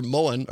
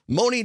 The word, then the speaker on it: Mone: UW-Milwaukee Chancellor Moen, or Moni, it's pronounced, Moni disputed many of the professor's critiques.